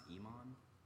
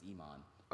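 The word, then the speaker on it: Imane: Her name is pronounced Iman. – Iman? – Mm-hmm. That's her, like, actual name. It's Iman.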